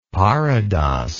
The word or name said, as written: parodos